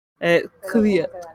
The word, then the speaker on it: Kvyat: Anyway, if you want to pronounce it authentically for the coming years, it's kind of pronounce it like you're swallowing it. So like it's in the back of your mouth. Kvyat.